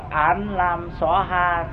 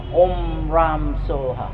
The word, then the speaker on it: Om Ram Soha: Thay vì đọc là Án Lam Xóa Ha theo Hán Việt Việt Nam đó, thì nên đọc là Om Ram Soha, Om Ram Soha, Om Ram Soha.